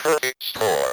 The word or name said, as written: perfect score